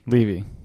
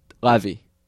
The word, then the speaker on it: Levy: Levy. – Okay, so then he's Dan Levy. – Dan Levy, not Levy. It's spelled the same way, though. – No, there's no A. It's L-E-V-Y.